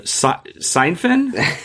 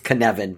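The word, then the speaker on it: Cynefin: It's Kenevin, but people Kenevin. Will see it. It's a Welsh word, so that the vowels and the consonants are all hard and soft in the wrong places, right? The hard C and the hard F.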